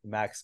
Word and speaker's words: Maks: Max Gabriel, you know, there's not many.